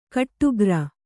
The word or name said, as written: ಗುಟ್ಟುಗಾರ